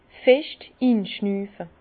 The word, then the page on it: fescht inschnüfe: Bas-Rhin; Herrlisheim